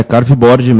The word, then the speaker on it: Carveboard: É isso? Falei certo? Tudo bom? – Tudo bem. É Carveboard mesmo.